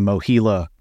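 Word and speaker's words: MOHELA: Thank you for pronouncing Mohila correctly.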